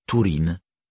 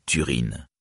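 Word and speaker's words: Túrin: U dans Uor, Urin, Turin par exemple, se prononce « ou » dans tous les cas. On dira donc « Turin » et non « Turin ».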